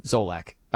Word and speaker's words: Solak: So the most entertaining watches, according to Ben Solak, how do you say his name? Solak.